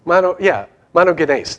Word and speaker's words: monogenes: If you just said it in English, what would you say? Monogenes. That's how it's. But what does it look like? One gene.